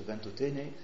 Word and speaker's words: Ventotene: Philippe Mioche, ici présent, en parlerait mieux que nous, hein, avec deux manifestes de résistants européens, le manifeste de Ventotene, j'ai bien prononcé, Guillaume.